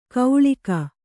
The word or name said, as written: ಕೌಳಿಕ